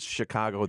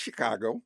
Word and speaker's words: Chicago: And I want you to pronounce Chicago the right way, It's Chicago. – Yeah. – Chicago. – Not the way I say it.